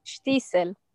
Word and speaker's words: Shtisel: It should have been spelled S-H-T-E-E-S-E-L. – Then we would have. So how do you pronounce it? – Shtisel. – Okay, perfect. You do it perfectly.